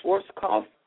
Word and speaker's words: Schwarzkopf: Norman Schwarzkopf.